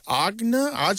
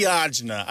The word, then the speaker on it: agita: Usually these days it is spelled A-G-I-T-A, but you might see it as Arjuna, A-R-G-A-D-A, or Agida, A-G-I-D-A. And the reason there's some variation in pronunciation and spelling is that for a long time it was only transmitted orally. It comes to mainstream English through Italian Americans and from dialects of Italian, where there's this word that means acidity or heartburn. It's pronounced something like acidita.